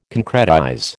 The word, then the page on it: concretize: American English